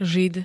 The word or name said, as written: žid